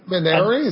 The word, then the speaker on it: Minari: Manary. Is that how I'm supposed to say that? – That's the proper way to say it from the Korean translation. I'm pretty sure.